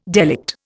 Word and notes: delict: Indian

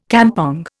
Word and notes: kampong: Indian

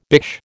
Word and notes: bish: American English

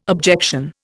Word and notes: objection: Indian

